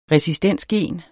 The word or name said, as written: resistensgen